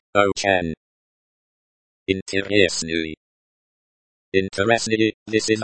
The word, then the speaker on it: интересный: Pronunciation is like интересный.